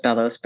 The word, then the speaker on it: Mpelos: It's pronounced Bellos, but if you're looking for it, it's spelled M-P-E-L-O-S.